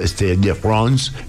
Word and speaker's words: Stade de France: The Weeknd, nada más y nada menos, acaba de batir un nuevo récord y fueron 6 fechas en París, en el estadio Stade de France, en París.